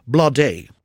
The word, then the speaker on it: Bladé: Alltså står det Blade på engelska då, fast med två prickar. Ja, det blir Bladé på engelska. Mm. Bladé på svenska. – Mm. Bladé. – Bladé.